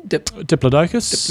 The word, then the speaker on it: Diplodocus: Diplodocus.